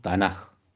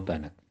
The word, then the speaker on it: Tanakh: Pero como en hebreo, esta K, digamos que en hebreo sería la Kof, cuando cae al último, cuando se escribe al último, adopta el sonido J. Entonces por eso decimos Tanakh, no Tanakh, para referirnos a la Micra.